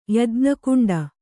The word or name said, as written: ಯಜ್ಞಕುಂಡ